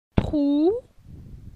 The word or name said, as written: Trou